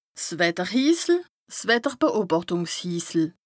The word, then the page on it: 's Wetterhisel, 's Wetterbeobàchtùngshisel: Bas-Rhin; Strasbourg